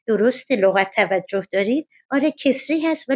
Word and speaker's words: کسری: تلفظ درست کسرا و کسری هر دو صحیحه درست رو شما میفرمایید ولی کسرام تلفظ کنیم ایراد نداره